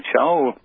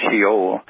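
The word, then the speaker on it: Shaul (שאול): Shaul, which is with a shin, an aleph, a wah, and a lamb. Pronounced Shaul, sometimes it's pronounced Sheol.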